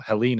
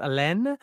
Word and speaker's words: Helene: Somebody who speaks a little bit of French, I want to call it Helene and not pronounce the H. I think that's wrong. I do like TV.